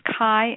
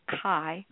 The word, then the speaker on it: Khi: His name is Kai, and it is spelled K-H-I. Kai Armand.